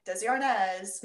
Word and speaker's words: Desi Arnaz: The host was, I'm not sure if I'm going to pronounce this right, Desi Arnaz. – There you go. – And he also did some singing and dancing as well. – Yeah, and yeah, the musical performances were just him, and Desi Arnaz Jr. was also credited.